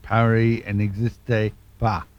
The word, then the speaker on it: Paris n'existe pas: Well, I wasn't too far out with my pronunciation, but it's Paris n'existe pas. So there you go. I've got the first two words, the first word and the last word right, and I've now learnt what the second one should sound like.